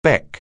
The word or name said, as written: beck